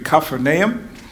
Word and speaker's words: Capernaum: Um, we say Capernaum in, in, English. It's really probably a better way to say it, to pronounce it would be Capernaum. It's a seaside town.